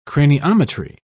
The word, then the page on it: craniometry: British English